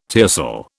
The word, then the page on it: tiercel: British English